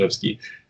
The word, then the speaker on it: Rzewski: it's spelled R-Z-E-W-S-K-I. It looks like Rzewski, but it's pronounced Zhevsky.